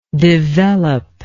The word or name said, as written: developability